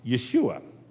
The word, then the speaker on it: Yeshua: It's in Hebrew, it's pronounced Yeshua, which in Greek is pronounced Jesus.